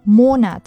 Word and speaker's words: Monat: 比如月份这个单词，它的重音其实应该在前，念作 Monat，但是有些同学会念成 Monat，Monat。